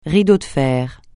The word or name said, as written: rideau de fer